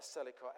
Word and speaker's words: Selichot: I think you say it like that, Selichot, S-E-L-I-C-H-O-T, if you want to Google it.